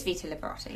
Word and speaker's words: Vita Liberata: But this leg stuff, it's called, how do you say this then? Vita Liberata? – I think it's Vita Liberata, yeah.